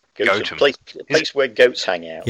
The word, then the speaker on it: Gotham: It's spelt the same as Gotham, yes, G-O-T-H-A-M, but it's pronounced Gotham.